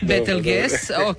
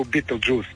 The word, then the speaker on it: Betelgeuse: Snimljanje pratitelj zvijezde, e sad da li ću ovo dobro izgovoriti, Betelgeuse ili Betelgeuse? – Betelgeuse. Neki članovi našega zovu Betelgeuse. Pa Betelgeuse, dobro, isto, lakše pamtivo.